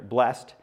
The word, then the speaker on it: blessed: Isn't that funny how we add that extra syllable there? Blessed is that servant whom the master finds doing his job when he comes.